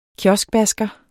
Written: KIOSKBASKER

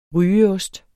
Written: rygeost

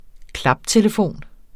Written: klaptelefon

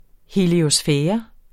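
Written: heliosfære

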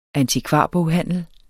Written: antikvarboghandel

